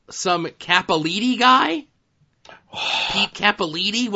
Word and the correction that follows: Peter Capaldi: What's his pronunciation? – Peter Capaldi. – Oh, is that how you pronounce that? – Yeah.